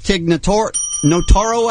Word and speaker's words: Tig Notaro: We have Sarah Silverman in with Tig Notaro, I believe is how you say her name.